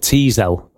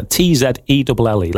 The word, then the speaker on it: Tzelle: Featuring Tiffany, I think you pronounce this Zell, is it? T-Zell, I think. It's just T-Zell. T-Z-E-L-L-E. Let's say T-Zell.